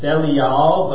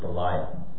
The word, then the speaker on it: Belial: It's more accurately pronounced Belial, but for the sake of something that I'll get to later in the sermon, I'm going to pronounce it Belial.